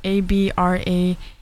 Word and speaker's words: Abraxas: And actually the album that I picked up is from that same song the album is from, and I believe it's pronounced Abracas. I think that's what it's pronounced. It's Abraxas.